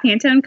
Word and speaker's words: Pantone: It's when you told me what the word Pantone, am I saying it right? – Yeah, Pantone color of the year.